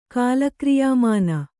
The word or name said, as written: ಕಾಲಕ್ರಿಯಾಮಾನ